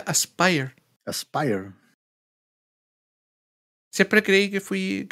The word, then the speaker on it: Aspyr: o sea, se pronuncia Aspire, pero se escribe A-S-P-Y-R.